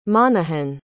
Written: monohan

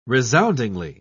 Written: resoundingly

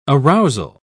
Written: arousal

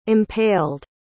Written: impaled

